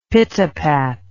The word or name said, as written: pitapat